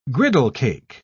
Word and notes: griddlecake: British English

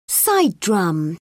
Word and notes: side drum: British English

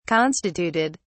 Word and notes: constituted: British English

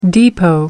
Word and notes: depot: American English

